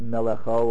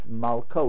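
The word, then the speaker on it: Malko (מלכו): Right, so actually it's not Melecho or Malacho, it's Malko, which means the original pronouncing was actually Malk.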